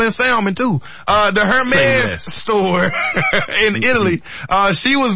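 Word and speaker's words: Hermès: Yep, Savannah James was on a podcast the other day and she was speaking about her experience at a Hermes store in Italy. – Hermes? – Is that how you, see, I always hit the H. – The H is silent.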